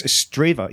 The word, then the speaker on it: Strathaven: So it's like Anstruther and Ainster I only discovered Strathaven or Straffy? It's Strava, yeah. – Yeah. – Oh really? – Straven or Strava, yeah. The locals pronounce it.